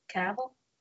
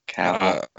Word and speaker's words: Cavill: Cavill. – Cavill.